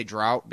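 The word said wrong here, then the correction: draught: No, I'm sure it's spelled D-R-A-U-G-H-T. – It is. – Yeah. It's just pronounced draft.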